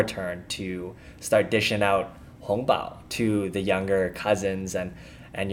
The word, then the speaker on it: hongbao: And by the way, hongbao is spelled H-O-N-G in the second tone. Hong, that literally means red. And then bao, B-A-O in the first tone, that literally means envelope.